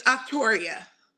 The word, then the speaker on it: Octoryia: It's Octoryia.